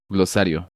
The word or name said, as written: Glosario